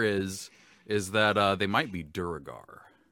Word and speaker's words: Duergar: The Forgotten Realms wiki, I checked the pronunciation guide just now, says Duergar is correct.